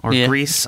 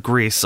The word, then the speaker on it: Gris: I bought Gris. Greece. I don't know how to say it anymore. – I think it is Gris. – No, because the guy who made it said Greece on stage at the Game Awards.